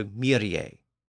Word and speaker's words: Myrje: The first word is Myrje, or Mary, M-E-R-R-Y. In the song, the word is given three distinct notes, so that suggests that it was intended to be sung as three syllables, not two. So Myrje.